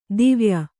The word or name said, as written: ದುವಯಿ